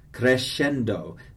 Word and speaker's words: crescendo: Crescendo, the S-C-E kind of has an S-H sound.